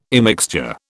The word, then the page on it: immixture: British English